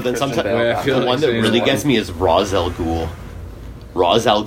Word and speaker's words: Ra's al Ghul: I'm confident that it's supposed to be pronounced Ra's al Ghul. – It is.